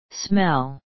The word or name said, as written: smell